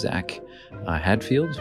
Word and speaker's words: Zak Hadfield: I'm sitting here with Zach Hadfield.